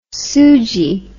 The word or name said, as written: soojee